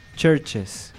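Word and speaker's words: CHVRCHES: La siguiente es Churches. O sea, es como decir iglesia, ¿no? Me imagino. O sea, churches. Dicho, escrito de la manera correcta. Nada más, aquí la única diferencia que hay en la escritura de este nombre de la banda es que nada más tiene una V.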